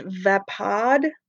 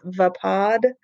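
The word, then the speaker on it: Vaapad: So Mace Windu uses Form 7, which is the, I believe it's pronounced Vapod variant. I've heard it pronounced a couple different ways. I'm going with Vapod.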